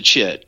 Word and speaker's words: chit: Am I saying that right? – Yes, sir. It's a chit.